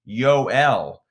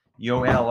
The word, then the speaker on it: Joel: The name, it's spelled J-O-E-L, Joel, but no, it's pronounced Yoel. Yoel, last name, Kivaranta.